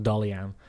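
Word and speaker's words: Daghlian: He's got an Irish surname. It's Daghlin, but I had to look it up phonetically. It's pronounced Dollyan.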